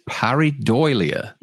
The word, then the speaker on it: pareidolia: Oh, but by the way, the term Dave is looking for is pareidolia. Is it? Is that how you pareidolia? – Pronounce it? – Pareidolia. – Pareidolia. Okay. Like when people see Jesus' face in their grilled cheese.